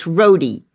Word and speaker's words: Rohde: I'm talking with Joe Rohde, and I apologize. I was pronouncing his name as Rohde. It's Rohde, and I should have known that.